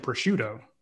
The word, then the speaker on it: prosciutto: When you get a chance, swing by the Yerpi location, grab a peach and prosciutto. – You said it right.